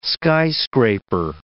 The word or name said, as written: skyscraper